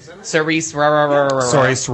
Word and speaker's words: Saoirse: Bridge of Spies, Brooklyn, Best Actress contender for, I can't pronounce her name, Saoirse Ronan.